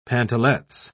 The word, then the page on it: pantalettes: British English